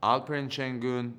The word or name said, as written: Alperen Sengun